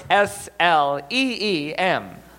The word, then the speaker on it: Sleem: Sleem. and you can spell that for us? S L E E M. Sleem.